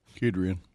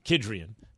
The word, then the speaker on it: Kedrian: Um, how's that trio, Coach Huggins, of uh, Kedrian? Did I say that right, Johnson? – Kedrian. – Kedrian.